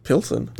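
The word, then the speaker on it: Plzeň: Pilsen? – That's actually how it's said.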